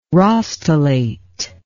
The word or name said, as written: rostellate